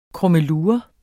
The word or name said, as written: krummelure